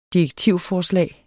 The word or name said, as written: DIREKTIVER